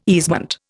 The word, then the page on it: easement: Indian